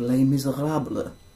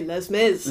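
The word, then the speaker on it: Les Misérables: But on the side, I also was in a production of Les Miserables. – Love that pronunciation. – I know. Yeah, I kind of speak French. – I just say Les Mis.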